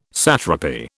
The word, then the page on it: satrapy: British English